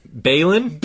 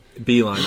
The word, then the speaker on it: Beilein: The Cavs fired head coach John Beilein. – Beilein.